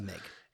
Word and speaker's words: MiG: Well, the first is I just want to cut off the corrections that we might receive at the pass. It is pronounced MiG. A MiG is a Russian aircraft. The MIG is a Russian aircraft company.